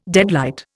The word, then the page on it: deadlight: Indian